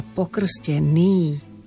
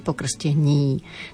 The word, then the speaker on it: pokrstený: Pozor, v prípade slova pokrstený nemôžeme v žiadnom prípade zmekčiť. Pokrstený, nie pokrstení, takisto slobodného, nie slobodného, takisto jeden, nie jeden.